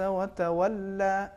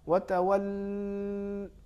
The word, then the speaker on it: وَتَوَلَّ: لہٰذا اس کا صحیح تلفظ یہ ہے عَبَسَ وَتَوَلَّ، بس اس حد تک. اگر ہم اس سے زیادہ کھینچیں گے وَتَوَلَّ، یہ تو صحیح نہیں رہا.